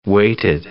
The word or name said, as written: weighted